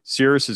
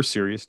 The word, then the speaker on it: Sirius: So when you go out on these sort of mid to late winter evenings, Cirrus is going to be, or Sirius, depending on how you say it, is going to be at the meridian, which means it's at the highest point.